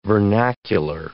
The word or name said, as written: vernacular